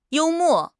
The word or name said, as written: you1 mo4